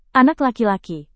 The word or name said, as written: anak laki-laki